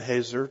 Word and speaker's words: Hazor: And very, very clearly, the context of Kedar, let me get the names right, Kedar and Hazor, there we go, or Kedar and Hazor, depends on which syllable gets the emphasis, as we like to say.